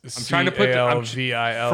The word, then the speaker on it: Calvillo: Is it Villo or Vio? Is it the Spanish pronunciation or is it Italian? – What's her first name? – Cynthia. – Cynthia Calvillo. – Calvillo? – Yeah. – It's Calvillo.